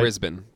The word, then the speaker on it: Brisbane: Did I say that right? – Brisbane, but go ahead. – Brisbane? – Yeah. – Well, why does it say Bane? – Because it's Brisbane. Continue.